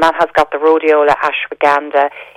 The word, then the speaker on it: ashwagandha: ashwagandha.